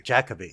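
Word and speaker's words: Jacobi: and I'm going to say this wrong too, Jacoby, right? – I think he says Jacoby. – Jacoby, yeah. – Okay. – Yeah, Derek Jacoby.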